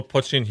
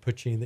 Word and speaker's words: Poitín: So, what is the most creative mispronunciation of Poitín that you've heard since you opened the restaurant? – Honestly, not a huge amount of variations, but definitely everyone calls it Poitín. That seems to be the standard.